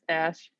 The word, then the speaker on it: Ashe: I think it's pronounced Ash. First, A S H E.